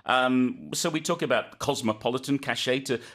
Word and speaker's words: cachet: Yes, cachet from the French, so we don't pronounce the T on the end. C-A-C-H-E-T, cachet. It just means that that state of having something.